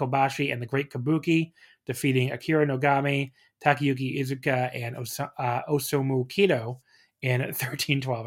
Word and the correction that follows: Osamu Kido: I totally butchered that. In 13-12. – I commend you for trying. – Osamu Kido is what I should have said in 13-12.